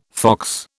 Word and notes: fox: British English

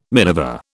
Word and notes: minever: British English

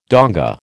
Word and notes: donga: American English

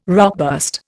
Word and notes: rockburst: Indian

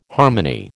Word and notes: harmony: American English

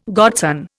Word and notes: godson: Indian

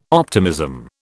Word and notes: optimism: British English

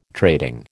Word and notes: trading: American English

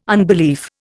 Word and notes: unbelief: Indian

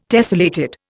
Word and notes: tessellated: Indian

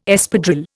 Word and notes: Espadrille: Indian

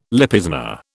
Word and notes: Lipizzaner: British English

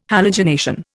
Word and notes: halogenation: Indian

